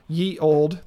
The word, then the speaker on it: ye: It's a dating sim where you are a octogenarian who has recently entered a ye old. They call it ye old, even though it's pronounced the. – Okay. – That Y-E in Old English is pronounced the.